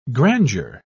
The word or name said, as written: grandeur